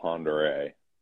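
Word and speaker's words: Pend Oreille: As the locals call it, Pend Oriel.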